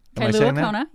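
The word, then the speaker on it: Kailua-Kona: The most useful and or colorful response comes from Joanna, who is apparently both in San Rafael, California, and Kailua Kona, Hawaii. – Kailua Kona, yeah.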